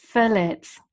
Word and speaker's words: phthalate: It's pronounced phthalate. – So you should have wrote it down phonetically. So you just wrote, yeah, she wrote it down for me phonetically. – Right, yeah. – Because it's spelt P-H-T-L. So that is weird, isn't it?